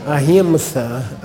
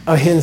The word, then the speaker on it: ahimsa: ahimsa, which is not pronounced ahimsa, okay? There's no such thing. You don't have there is no M before an S in any Sanskrit word at all.